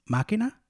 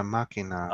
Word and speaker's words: Makina: I think that it would actually be pronounced Makina, which means machine. – Yeah, Makina.